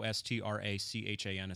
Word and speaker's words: Strachan: I'm on Twitter at O S T R A C H A N. It's Scottish last name with a Gaelic pronunciation.